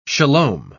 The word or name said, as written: shalom